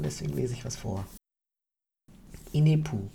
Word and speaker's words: Inepu: Inepu ist die, mit Betonung auf dem I, Inepu.